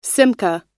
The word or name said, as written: simcha